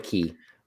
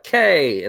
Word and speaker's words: Cay: It's spelled Castaway Cay. – Yeah, but it's Castaway Cay. – Why? – Why? – Because it's a Caribbean man. – I'm calling somebody on this. – That's how you say it. I when I was first started doing these cruises too, I'm just like K, and they're like, no.